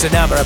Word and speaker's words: Enamor: It should be pronounced Enamor apparently, but he's given up worrying about how people say it, as long as they remember the name.